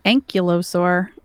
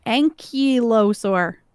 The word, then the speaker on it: ankylosaur: All right, you felt like an ankylosaur who might be in danger.